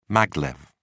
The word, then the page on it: maglev: British English